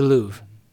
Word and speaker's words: Louvre: kind of looks like the Louvre in France which I'm sure I mispronounced that. – The Louvre, just the Louvre.